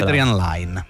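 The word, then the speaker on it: Adrian Lyne: Si intitola Proposta indecente di Adrian Lane, come si pronuncia? – Adrian Lane. – Lane, questo lo dici tu? – Lo dico io.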